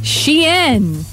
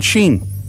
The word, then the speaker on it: Shein: and it is pronounced Shein. – Not Sheen or no. Shine. – Nope.